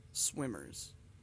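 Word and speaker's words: SWMRS: And the band is Swimmers, but it's spelled all fucked up. It's S-W-M-R-S, Swimmers.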